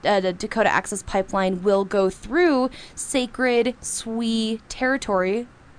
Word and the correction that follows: Sioux: Is that, did I say that right? – Sioux territory. I knew I said that wrong.